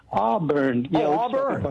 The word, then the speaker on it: Auburn: In Arbor, California, am I saying it right? – Auburn.